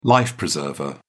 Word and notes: life preserver: British English

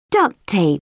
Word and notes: duct tape: British English

